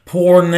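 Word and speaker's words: porne (πόρνη): It is a word that is pronounced porne.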